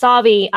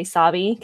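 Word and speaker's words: Sauvie: The end of it is the outtakes of him trying to remember how to say Savi because it's spelled like Suave.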